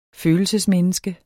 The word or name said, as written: følelsesmenneske